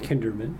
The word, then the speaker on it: Kinderman: Yeah, my name is Mike Kinderman, K-I-N-D-E-R-M-A-N.